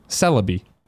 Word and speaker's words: Celebi: Zyger says Celebi is pronounced Celebi.